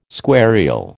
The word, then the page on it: squarial: American English